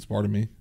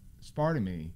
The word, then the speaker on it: aspartame: At least you say it right. – Like, maybe, why? How would people? Aspartame? – Aspartame. – Oh, aspartame. – That's how they say it. You say it right, then.